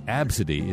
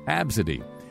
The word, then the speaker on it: Abcde: Tracy Radford and her daughter, Absidy, name is pronounced Absidy, is spelled A-B-C-D-E. Absidy.